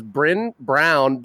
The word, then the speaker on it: Brynn Brown: This is Brynn Brown. Brynn, did I get your name right? – Yes, you did.